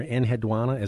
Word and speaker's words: Enheduanna: There's a name you bring up in here, Enheduanna.